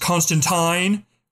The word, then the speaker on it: Constantine: It's pronounced Constantine. Rhymes with turpentine.